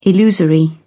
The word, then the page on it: illusory: British English